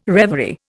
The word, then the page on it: reverie: Indian